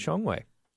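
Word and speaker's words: Shuanghui (双汇): Let's start off with, if we could, a merger that would make pork giant Smithfield Foods a subsidiary of the Chinese company, I'm hoping I'm saying it correctly, Shuanghui? – That's my understanding, yeah. Shuanghui okay.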